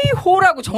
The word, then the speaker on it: 헤이호: 왜냐면 제가 이거 불렀을 때 어렸을 때 헤이요 이렇게 불렀는데 아까 해주신데 헤이호라고 정확하게 발음을 해주시더라고요.